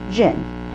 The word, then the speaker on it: djinn: Now, when I say jinn, it's spelled D-J-I-N-N. Jinn.